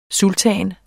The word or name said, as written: sultan